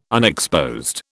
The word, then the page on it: unexposed: British English